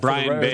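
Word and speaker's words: Brayan Bello: I believe it's Brian Bayo. – Yeah, Brian Bayo.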